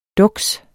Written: duks

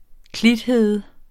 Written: klithede

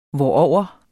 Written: hvorover

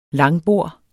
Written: langbord

